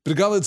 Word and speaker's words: Bregalad: I struggle with the pronunciation of this, right? Bregalad, I think, is the correct pronunciation, but because it has that Celtic kind of Scots, Gaelic kind of morphology to it, I want to pronounce it in a Scots way, and I always did pronounce it in a Scots way when I was reading The Lord of the Rings originally, where it would be Bregalad, you know, it would be a harder sound than that, and that doesn't sound entirely Entish to me, so forgive me.